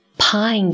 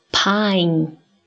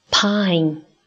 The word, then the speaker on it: pine: 我们先来读 pine 这个单词。请你来跟我读 pine pine。那么要注意，很多人会把这个词读作 pan 或者是 pin，这些都是不正确的。